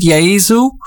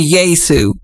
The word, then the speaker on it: Yaesu: And by the way, you're supposed to pronounce it Yaesu, but Americans, we say Yaesu.